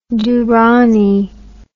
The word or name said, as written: durani